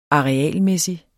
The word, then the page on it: arealmæssig: [ ˌɑːeˈæˀlˌmεˀsi ]; [ -ˌmεsi ]